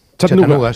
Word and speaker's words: Chattanooga: ¿Se pronuncia así en Chattanooga? – Chattanooga. – Sí.